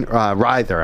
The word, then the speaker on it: Ryther: Deanna Jean Ryther.